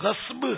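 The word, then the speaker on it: غضب: صحیح تلفظ غصب ہے، غصب غین سواد با، سواد ساکن ہے بیچ میں، غصب.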